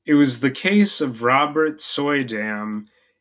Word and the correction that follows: Suydam: and apologies if that one is not pronounced correctly, it's Suydam, which took Malone to the heart of things in Red Hook.